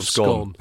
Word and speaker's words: Scone: To correct the pronunciation the Stone of Scone.